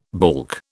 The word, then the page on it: baulk: British English